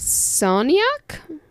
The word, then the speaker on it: Soniak: was written by Max Soniak. – Yeah, Max S. – Rhymes with cognac.